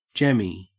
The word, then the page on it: jemmy: Australian English